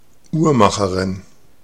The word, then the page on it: horloger: France; [ɔʁ.lɔ.ʒe]